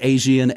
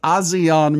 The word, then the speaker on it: ASEAN: Do they pronounce it ASEAN, A-S-E-A-N? The Association of Southeast Asian Nations. I'm just going to say ASEAN. I think they go with ASEAN, but whatever.